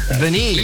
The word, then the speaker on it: Benee: B-E-N-E-E. Is it pronounced Beanie? – Beanie. – Yes.